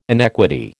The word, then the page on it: inequity: American English